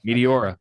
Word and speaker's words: Meteora: All right, so let's talk about Meteora. Did I say that right? Meteora? – Yes, Meteora, yeah.